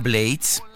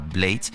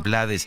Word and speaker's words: Blades: Rubén Blades, así se pronuncia su nombre, es un nombre inglés, él mismo lo pronuncia Blades. Aquí en México lo conocemos con el apellido españolizado, Rubén Blades, eh, uno de los creadores de la salsa ya en los años 70 en la ciudad de Nueva York.